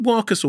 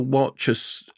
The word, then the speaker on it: Warchus: Is it Warkus or Warchus? How do you pronounce it? – I haven't actually said the word out loud. I think she said Warkus. – Warkus, beg your pardon.